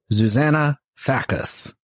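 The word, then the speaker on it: Zsuzsanna Fazekas: It's spelled ZSUZANNAFAZEKAS. Zuzana Fackus and the Angel Makers.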